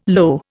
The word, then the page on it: lo: Indian